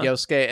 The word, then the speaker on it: Yosuke: Yeah, and your friend Yosuke, is that how you say his name? – Uh-huh. – Yosuke,